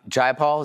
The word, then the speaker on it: Jayapal: Jayapal, is that how you say it? – Yep.